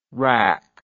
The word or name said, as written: WRAAC